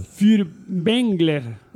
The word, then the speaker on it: Furtwängler: Wilhelm Fürbengel. – ¿Qué pasa? Si lo pronuncio bien. ¿Furtwängler o Fürbengel? – Furtwängler.